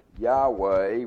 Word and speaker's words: Yahweh: And that comes from the word Yahweh, YHWH, Yehovah, Yahweh, we think it's pronounced.